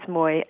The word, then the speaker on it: Mui: Yes, it's Mui.